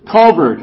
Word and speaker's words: Calvert: Yes, I spent Culvert is the way to pronounce it, by the way.